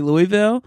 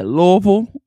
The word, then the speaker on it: Louisville: You know, I think it's so amazing that's how you say it, because I would say Louisville, but I love how they say Louisville.